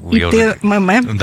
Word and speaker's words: ИТММ: ИТТМ ИТММ. – Да, ИТММ.